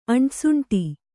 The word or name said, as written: ಅಂಟಸುಂಟಿ